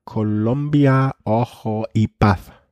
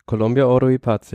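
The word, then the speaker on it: Colombia Oro y Paz: Und ist bei der Columbia Ojo Paz. Erstaunlicherweise richtig ausgesprochen. – Columbia Oro Ipath, ja.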